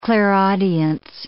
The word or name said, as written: clairaudience